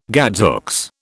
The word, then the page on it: gadzooks: British English